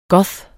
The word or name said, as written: goth